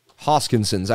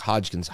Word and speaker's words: Hoskinson: Hoskinson's. I always say it wrong, like Hodgkins. Hoskinson's. Hoskinson. You know.